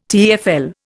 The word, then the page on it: TEFL: Indian